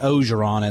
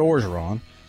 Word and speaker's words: Orgeron: Not Ogeron, as some people say, but Orgeron. Not a silent R.